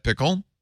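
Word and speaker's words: Pkl: I'm pretty sure you pronounce that pickle.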